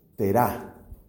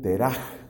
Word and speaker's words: Terá: Resulta que el papá de Abraham es un hombre que se llama Terá. Otros escriben Teraj con una J y otros acentúan Tera. Entonces hay esas distintas pronunciaciones. Vamos a quedarnos aquí con el nombre Terá.